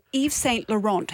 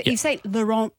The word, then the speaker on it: Yves Saint Laurent: I don't think you pronounce the T on the end of Laurent.